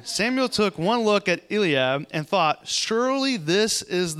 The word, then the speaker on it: Eliab: And in 1 Samuel chapter 16, verse 6, it says, When they arrived, Samuel took one look at Elab, and thought, Elab, Eliab. How do you say that? Elab, Eliab. Eliab. That's That sounds a lot better. Eliab, yes.